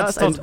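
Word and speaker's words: Arstotzka: Äh, nämlich an der Grenze zu, also ich kann es mal nicht richtig aussprechen, Astotska oder so, ähm, ist eine... Astotska. – Ja, Aztotska.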